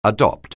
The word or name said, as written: adopt